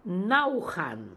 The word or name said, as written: Naookhan